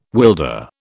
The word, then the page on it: wilder: British English